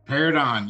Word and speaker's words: Paridon: Paridon, yes.